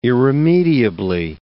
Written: irremediably

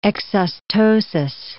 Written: exostosis

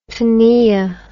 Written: pnoea

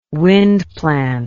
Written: wind plant

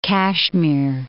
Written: Jammu and Kashmir

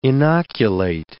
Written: inoculate

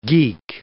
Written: geek

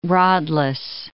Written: rodless

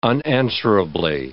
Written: unanswerably

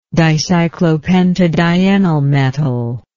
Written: dicyclopentadienylmetal

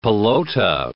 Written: pelota

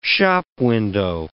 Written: shopwindow

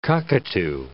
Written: cockatoo

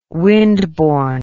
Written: windborne

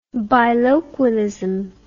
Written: biloquialism

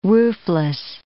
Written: roofless

